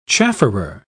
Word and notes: chafferer: British English

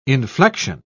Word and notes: inflexion: British English

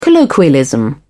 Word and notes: colloquialism: British English

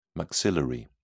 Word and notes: maxillary: British English

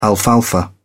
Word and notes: alfalfa: British English

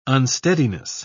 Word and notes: unsteadiness: British English

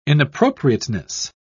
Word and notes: inappropriateness: British English

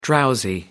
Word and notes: drowsy: American English

